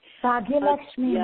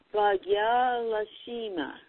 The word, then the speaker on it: Bhagyalakshmi: And I like to be able to pronounce words, names correctly. – Bhagyalakshmi Ma. – Yes, Bhagyalakshmi, right? – Lakshmi Ma, yes.